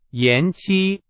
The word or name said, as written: 延期